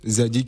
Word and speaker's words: Zaady: Zadi qui s'écrit Z deux fois A, D Y officiel. Ensuite à Facebook Zadi Z A A A D Y.